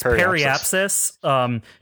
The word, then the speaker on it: periapsis: It's periapsis.